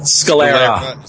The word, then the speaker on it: Scalera: And Scalero is a fucking beast, man. – Scalera. – Is good too. – Scalera. Yeah. – Scalera. Oh, I've been mispronouncing names all night, so.